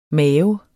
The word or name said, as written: mave